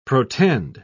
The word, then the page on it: protend: British English